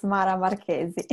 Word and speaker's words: Mara Marchesi: It's Mara Marchesi.